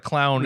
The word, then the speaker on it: Clow: When she's not dealing with the Clow cards, which I think it's I'm pretty sure it's pronounced like it's supposed to be pronounced Clo, like C-L-O-W, like glow with a C, but like the 4Kids version said Clow, and hey, it's been decades, I'm not going to change my ways now.